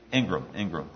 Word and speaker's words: Ingram: And Ken was referring to an experience that Chip Abram, is that how you say it? Ingram, right,